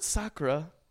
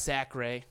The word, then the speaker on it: Sacre: Roy Hibbert, Robert Sacre, Meta World Peace, and Ryan Kelly. – It's pronounced Sacre.